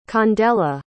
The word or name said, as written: candella